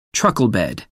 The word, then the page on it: truckle bed: American English